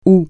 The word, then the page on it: aorte: francais